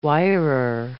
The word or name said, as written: wirer